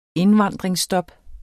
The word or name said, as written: indvandringsstop